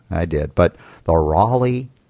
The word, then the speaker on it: Raleigh: like, don't say Raleigh, it's Raleigh.